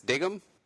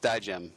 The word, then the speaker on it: Digem: Tony reads his name wrong. His name is actually Digem. But Tony sees it and he says, "What is this, Diggum?" He's like, "No, it's Digem."